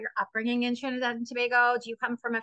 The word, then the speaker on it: Tobago: We call it Trinidad and Tobago. There are others who say Tobago, but you know what? Once they get here, it doesn't matter how you pronounce it.